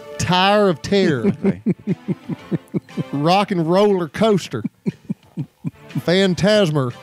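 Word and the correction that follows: Fantasmic: Phantasmic, which we named our number one show.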